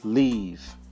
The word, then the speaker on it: leave: See, this leave is the long E sound. So I'm going to leave Shanghai. I'm going to leave Shanghai. So usually if you don't pronounce it in a way that we can feel that is the long E sound, people may think you're saying live, which is the short E sound.